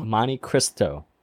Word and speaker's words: Monte Cristo: No, you said Crisco. Like you calling it like the cooking wheel. – You guys making fun of my accent again? – It's not an accent if you pronounce it wrong. – That's a mispronunciation. Show the legacy brand some respect, goddammit. – Put some F on their name, bro. – Monte Cristo. – There you go. – Yeah, Monte Cristo.